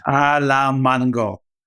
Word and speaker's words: Alamango: Alamango.